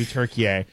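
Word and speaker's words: Türkiye: Yeah, well, where it came from is Turkey went to the UN and said we would like our English pronunciation to be Turkey A.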